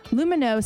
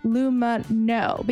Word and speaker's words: Lumineux: Lumino, spelled L-U-M-I-N-E-U-X, is the first oral care line designed to strengthen your oral microbiome, saying no to lots of ingredients that can harm it. That's why they pronounce their name Lumino, because it has no harsh bleaches, no artificial dyes, no alcohol.